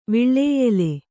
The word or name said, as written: ವಿಳ್ಳೇಯೆಲೆ